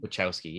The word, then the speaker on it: Wachowski: Wachowski? – Wachowski, yeah.